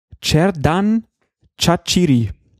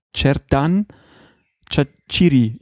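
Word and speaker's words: Xherdan Shaqiri: Auf der letzten Silbe wird betont im Albanischen und deswegen heißt der Mann dann Cerdan Chachiri irgendwie.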